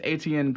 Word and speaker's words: Etienne: But E-T-I-E-N-N-E, Etienne? – Etienne? – Etienne? – Yeah. – Sure. – Etienne. – The French scientist Etienne Klein has... – Etienne Klein. – Etienne Klein has been forced to apologize... – Like a money machine, but with an N.